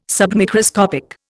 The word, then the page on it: submicroscopic: Indian